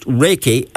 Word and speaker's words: Reiki: So it's pronounced Reiki.